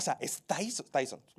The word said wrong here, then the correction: Dyson: ¿Lo estoy pronunciando bien? Dyson, ¿ok?